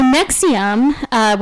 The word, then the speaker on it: NXIVM: Anyway, so Nexium, which is spelled N-X-I-V-M, because they're cool.